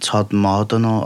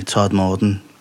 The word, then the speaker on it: Todmorden: Someone told me you pronounce it Todmorden, Todmorden.